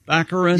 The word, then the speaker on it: Baccarin: Baccarin? – Baccarin.